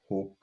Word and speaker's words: Hope: Es wird Hope gesprochen mit langem O.